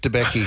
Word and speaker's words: Debicki: Elizabeth Debicki.